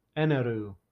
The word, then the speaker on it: Eneru: Because of the accent, it would be Eneru. Like, because the L, their L's are R's and their R's are L's.